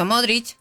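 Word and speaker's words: Modrić: Pero es con CH. – Por eso, Modric. – No, sí. – Claro, se pronuncia con CH.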